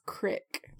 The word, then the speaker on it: creek: Well, another pronunciation that's very local here is crick, like shell crick, meaning creek.